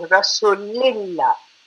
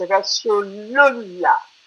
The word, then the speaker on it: Rasulullah: The Jamaat constantly says Rasulillah. It's not Rasulillah, it's Rasulullah. La ilaha illallahu Muhammadur Rasulullah Aliyun Amirul Mu'minina Aliyullah.